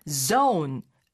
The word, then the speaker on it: zone: 나 오늘 zone 안에 좀 있는 것 같아 라는 말이 될 텐데 이게 우리말로는 존이라고 해서 뭐 스쿨 존 이렇게 말할 적에도 존이라는 단어를 많이 쓰는데요. 사실 right. 어떤 영역이란 뜻으로 쓰게 되긴 하는데 그러나 zone 이렇게 발음해야 상대가 알아들을 수 있다고 합니다. – Right, zone. – 네. – Z-O-N-E, zone.